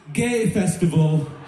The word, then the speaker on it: G Festival: But then I found out that you pronounce G-Festival in Faroese as gay festival.